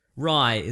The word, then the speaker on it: Rai: Rai is the name of the samurai. – Gotcha. – Yeah. – Like the bread. – Like the bread. Yeah. – Good. – Rai. – How do you spell it? – R-A-I. Rai. – Not like the bread, though.